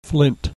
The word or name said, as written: flint